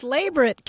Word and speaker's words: labret: Labret. Yeah. Stress on the first syllable.